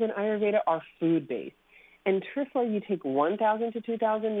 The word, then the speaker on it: Triphala: So first, one of my favorite herbs is Trifla. It's spelled T-R-I-P-H-A-L-A.